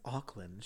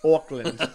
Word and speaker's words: Auckland: She was like, I was like, I'm pretty certain it's Auckland. She goes, what do you mean? I was like, it's Auckland. She's like, oh, what? And so then I like showed her like, that's how you say it. And she's like, oh, I guess I just like heard everyone's accents. Auckland. – Oh. – Auckland. So it sounds like Oakland to Auckland.